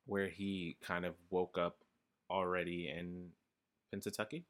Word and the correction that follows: Punxsutawney: Did I say that right? – Punxsutawney, thank you.